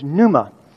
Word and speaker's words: pneuma: And it is pneuma, which is spelled P-N-E-U-M-A, like pneumonia. Sometimes I've seen it spelled phonetically, N-O-O-M-A.